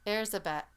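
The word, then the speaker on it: Erzsébet: Or Erzbeth. – Hmm? – Yeah, that's how they say it, Erzbeth. Erzabeth, that's how they say it.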